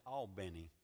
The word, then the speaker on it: Albany: Now down there, they pronounce it Albany, but it was Albany, Georgia, and I was preaching a revival for First Free Will Baptist Church of Albany.